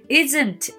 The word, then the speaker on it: isn't: Isn't it? の isn't の t ははっきり発音しなくて大丈夫で i s n t isn't it?